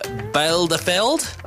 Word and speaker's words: Bielefeld: Well, a German town, I'm going to try and pronounce it, Bielefeld. – Oh, yeah. – Simo, you have German ancestry. Did I pronounce that correctly? – Yeah, I don't know, maybe. – There you go. Do you want to have a go at pronouncing the name? – No, I reckon you got that right.